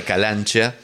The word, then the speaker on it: Calancea: Y entre los nombres que me pasó, estaba el de este grupo, que se llama Lupi Calancha. En verdad Calancia se escribe, pero parece que se pronuncia Chea o una EA junta. – Ahí va. – Entonces queda Calancha.